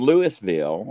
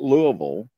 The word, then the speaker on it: Louisville: But he lives in Indiana, and which is right across the bridge from Louisville, or we call it Louisville. But we, you know, it's spelled Lewis.